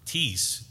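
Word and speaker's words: tes (τῆς): But when you break down that word, tes, T-E-S, long E, tes, where we see of or to, this is tough stuff.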